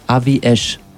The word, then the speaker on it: Aviash: Aviash. – Nachdem ich es 8-mal verschieden gebutschert habe. – Egal. – A, und alle gemeinsam. A, wie Ash. Ja, sehr gut, richtig.